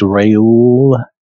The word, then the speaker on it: Reuel: Now Moses was keeping the flock of Jethro, and then it has in parentheses Reuel, and it's hard for me to pronounce that. It's Reuel, type of R-E-U-E-L.